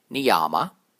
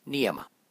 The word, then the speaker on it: niyama: In the word niyama, both the A's are short. If you make the first A long, then you get a common mispronunciation of this word, niyama. In reality, both the A's are short, so it's said as niyama.